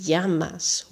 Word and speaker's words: llamas: En als je twee L'en naast elkaar hebt samen, dan spreek je die uit als J. Bijvoorbeeld in de vraag Como te llamas? Hoe heet je? Llamas schrijf je met twee L'en, maar je zegt je.